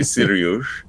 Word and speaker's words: Cyrus: Yes, and Kevin, you've given the Persian pronunciation of Cyrus, Sirius. His name is Cyrus Pittman. But in Iran, you say Sirius.